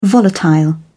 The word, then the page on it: volatile: British English